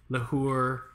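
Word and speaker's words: l'heure: Hermès, Arceau Lahore, Lahore. Yeah, because H is silent.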